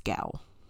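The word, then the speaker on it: gaol: Gow. I want to say goal because my brain keeps trying to flip the O and the A. But it's gow.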